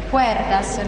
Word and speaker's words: Cuerdas: Insomma, si chiama Quertas, andatelo a cercare perché è molto carino, forse anche da far vedere ai bambini. – Cuerdas, lei mi dice come si scrive.